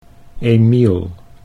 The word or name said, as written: Emiel